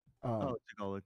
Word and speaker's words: Tagalog: There's also a lot of, a lot of them spoke, I don't know how to say it, Tagalog. – Tagalog, yeah.